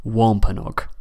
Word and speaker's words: Wampanoag: The chief who was allied to Plymouth and who journeyed there for Thanksgiving should be pronounced Massasoit, and his tribe should be pronounced Wampanoag.